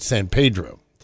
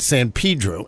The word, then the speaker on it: San Pedro: which if you looked at it, you would pronounce it San Pedro. But they pronounce it San Pedro, and I found that out when I got there.